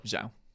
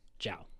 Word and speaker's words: Zhao: So Zhao, I think is how you say it. Z H A O. – Zhao. Zhao.